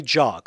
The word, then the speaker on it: Zhao: Trust me, I looked this up. It's pronounced Chloe Zhao.